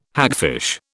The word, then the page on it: hagfish: British English